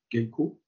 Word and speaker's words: Geico: Est-ce que je le dis bien, man? Geico, comme ça pour nous. – Magnifique, c'est le Geico Nationals.